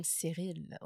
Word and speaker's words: Cyril: What a name, Cyril. I would think it's a very scrawny name. – It rhymes with viril.